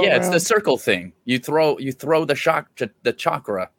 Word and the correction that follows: chakram: It's a chakram. I'm sorry, I said it wrong. Chakram. Chakram.